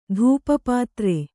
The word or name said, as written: ಧೂಪಪಾತ್ರೆ